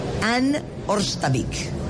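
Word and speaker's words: Hanne Ørstavik: Eh y es eh, Editorial Océano, Anne Orstavik. Did I say it right? Did I learn it at last? Yes.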